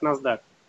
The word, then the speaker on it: NASDAQ: Читается как NASDAQ.